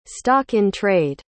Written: stock-in-trade

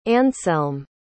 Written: anselm

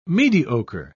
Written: mediocre

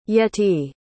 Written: yettie

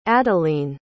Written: adaline

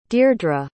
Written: dierdra

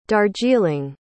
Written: darjeeling

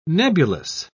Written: nebulous

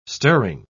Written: stirring

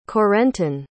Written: Corentin

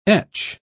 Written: etch

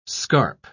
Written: scarp

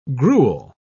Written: gruel

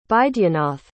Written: baidyanath

